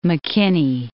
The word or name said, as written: McKinney